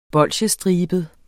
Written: bolsjestribet

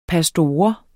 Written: pastor

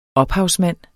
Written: ophavsmand

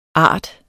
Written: art

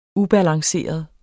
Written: ubalanceret

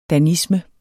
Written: DANISMER

